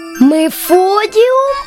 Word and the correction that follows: Нефродіум: Та ні, Нефродіум гірський.